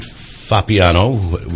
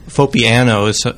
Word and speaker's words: Fappiano: And I think some emphasis on the A and the N. Fappiano is how I hear it around the winery once in a while, too.